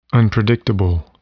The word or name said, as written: unpredictable